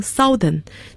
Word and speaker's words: Southern: N-O-R-T-H-E-R-N 那么南方的英文是 S-O-U-T-H-E-R-N 要注意发音，很多人有可能读成 Southern, 但是正确的发音是 Southern, Southern OU 字母组合读成了啊这样的声音。